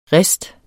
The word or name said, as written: riste